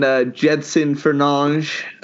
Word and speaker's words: Jedson Fernandes: And then Jedson Fernandes. Apparently, that's how you pronounce his last name. I listened to some Portuguese YouTuber.